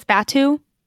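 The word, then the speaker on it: Batuu: Oh, while we're on, I mean, sort of the topic on Galaxy's Edge, because the planet there is it Batu? Is that how we're pronouncing it? – I think it's Batu.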